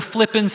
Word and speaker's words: Flippin: I said that right, F-L-I-P-P-I-N, Flippin, Arkansas, which has a Flippin Baptist Church and a Flippin Church of Christ. You know, they're ecumenical, right? When you're growing up, you go to the flippin' school.